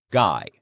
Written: guy

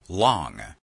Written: long

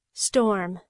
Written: storm

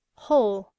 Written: whole

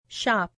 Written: shop

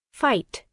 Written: fight

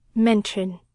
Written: mention

